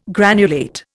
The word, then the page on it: granulate: Indian